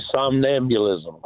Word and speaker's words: somnambulism: The somnambulism question. – Oh, all wow. Right. He said it right, man. – He didn't struggle pronouncing that.